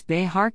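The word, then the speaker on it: Bexar: It's Behar County, not Bear County. Editor: Spanish is probably the easiest language to learn because the vowel sounds never change. The modern pronunciation of Bear County is egregious, aggravated assault on one beautiful language. It originates from Behar. The J in Spanish is pronounced like H in English, as in Behar.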